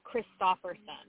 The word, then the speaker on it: Christopherson: It is officially pronounced Nicole Christopherson.